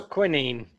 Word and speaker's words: quinine: How do you pronounce quinine? – There you go. Quinine.